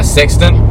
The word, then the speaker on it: sextant: We got a sextant. A 9 weight sextant to try. – A 9 weight sextant. – Yeah, yes. Sextant. – Yes. – Let's pronounce the T.